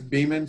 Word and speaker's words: Beaman: Last name is Beaman, spelled like be a man.